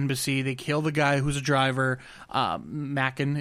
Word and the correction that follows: Macon: is that how you say it? Mackin Blair, whatever. – Macon. Macon, Macon, Georgia. Macon bacon.